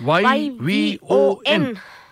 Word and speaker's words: Yvon: Y V O N.